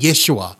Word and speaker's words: Yeshua: And then in the Hebrew they would pronounce it Yeshua.